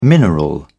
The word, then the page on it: mineral: British English